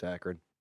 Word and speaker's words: Akron: Akron, Ohio. The police in Akron, Ohio are searching for a man who is caught... Akron? – Mm-hmm. It's Akron. – Akron? – Yeah. – A-K-R-O-N.